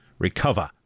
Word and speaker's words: Recuva: A program you might think should be pronounced recuva is actually pronounced the way a Bostonian might say recover, recuva.